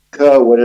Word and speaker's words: Kirwan: Well, my name is Ed Kirwan, and that's spelled K-I-R-W-A-N.